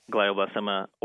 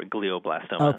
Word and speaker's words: glioblastoma: Some people pronounce it glioblastoma or glioblastoma.